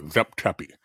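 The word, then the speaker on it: Zep Tepi: That's how they pronounce it, the time before, Zep-Tepi.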